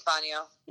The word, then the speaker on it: Fangio: It could have a soft G sound like Fangio. But that would be unusual. But yeah, I think Fangio is fine.